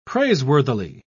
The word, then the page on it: praiseworthily: British English